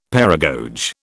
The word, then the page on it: paragoge: British English